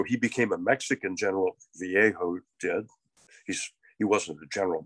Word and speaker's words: Vallejo: But a man named General Mariano Guadalupe Vallejo is how people pronounce it here.